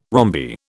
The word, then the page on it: rhombi: British English